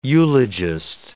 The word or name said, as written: eulogist